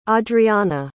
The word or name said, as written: audreana